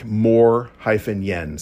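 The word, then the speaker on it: Moien: It is pronounced something like Mor-Yen, so M-O-R-E-Y-E-N, Morgen. So Morgen.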